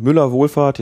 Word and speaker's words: Müller-Wohlfahrt: Ja, und wer Hans-Wilhelm Müller-Wohlfahrt,